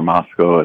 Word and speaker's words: Moscow: Yeah, so speaking of Moscow, or Moscow as it's locally pronounced, I think they're changing that due to this.